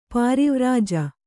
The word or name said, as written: ಪರಿವ್ರಾಜ